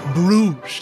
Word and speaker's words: Bruges: Bruges.